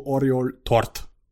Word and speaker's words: Oriol Tort: Ich hoffe, ich habe es richtig ausgesprochen. – Ich sag's nochmal. – La Masia Centre de Formacio Oriol Tort. – Ja, klingt gut.